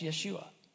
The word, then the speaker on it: Yeshua: See, in the Old Testament, Joshua, the word in Hebrew, is pronounced Yeshua.